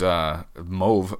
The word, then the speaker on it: Mauve: Learn that because Mauve rhymes with stove, according to an old Network release that was on the I Die, You Die Twitter feed.